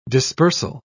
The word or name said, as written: dispersal